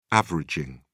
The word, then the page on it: averaging: British English